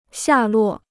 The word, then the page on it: 下落: xià luò